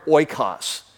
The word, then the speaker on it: oikos: The first word is the word oikos. And so it's spelled like this, O-I-K-O-S, oikos, O-I-K-O-S.